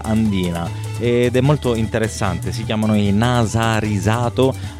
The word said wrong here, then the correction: Narasirato: vado a controllare se l'ho detto bene, Narasirato, anzi, questa è la pronuncia giusta, ed escono anche loro lo scorso anno 2012 con Baratoho.